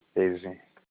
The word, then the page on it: ezin: Olukumi